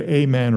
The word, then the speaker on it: amen: You say it amen or amen, depending what mood I'm in. I say it both. You pronounce the long A or the short A.